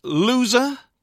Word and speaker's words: Louser: Before Coolidge, Loser, but it's spelt Louser, Chris Grau.